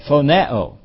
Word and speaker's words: phoneo: And it's pronounced phoneo. Yeah, there it is. Phoneo, if I can read my own writing here.